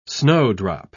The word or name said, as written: snowdrop